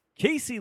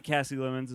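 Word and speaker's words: Casey Lemmons: Not Cassie Lemons, as we mistakenly pronounced her name last episode. – So, yeah, Google is bad at this. – Yeah, we just Googled it, and it like kind of does that like Google pronunciation thing, and it said Cassie, right? – Yeah. – And then I was doing the actual research, and IMDb told me it was pronounced Casey, and then I actually did proper research and listened to a couple podcasts and interviews that introduced her.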